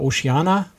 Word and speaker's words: Oceania: Ähm, Oceania Cruises oder Oceana, wie, wie es ausgesprochen wird, und eben Regent äh, Seven Seas Cruises.